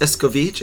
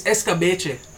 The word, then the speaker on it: Escabeche: You know, it's not pronounced Escovich. It's Escovich. It's Escabeche.